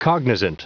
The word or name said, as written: cognizant